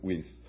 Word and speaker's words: Wuest: You're saying, how do you spell that? W-U-E-S-T. He pronounced it Weiss.